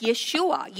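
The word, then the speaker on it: Yeshua (ישוע): So, in English, it says Jesus, but in his original pronunciation, it's Yeshua, Yehoshua, the same meaning, which means the Lord saves.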